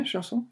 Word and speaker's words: chanson: Talking about music genres, I always wonder what are some of the most representative artists from the well-known French chanson, right? Mm-hmm. Am I pronouncing it right? – Yeah, chanson.